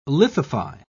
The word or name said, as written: lithify